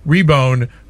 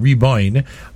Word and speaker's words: Riboin: Reboin, which is spelled R-I-B-O-I-N, Reboin, I found this song.